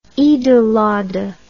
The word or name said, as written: eid ul adha